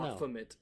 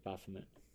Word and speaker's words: Baphomet: Scudder also fashioned a stained glass window adorned with the prophet known as Bahamut or Is it no? – Baphomet. – Yeah, it was a Baphomet.